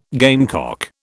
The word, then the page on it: gamecock: British English